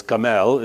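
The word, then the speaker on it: Kamel (גמל): Kamel is how you say it in Hebrew, and it comes right to us in the form of camel through the Greeks and the Latins, etc.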